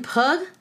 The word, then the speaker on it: Pugh: Linda Hoffman Pug. Her last name is spelled P-U-G-H. Pug. Pug.